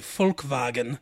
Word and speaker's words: Volkswagen: but in German, V's are F's and W's are like pronounced like F's and W's are pronounced like V's. So in German, they would say Volkswagen, and it means family wagon family car.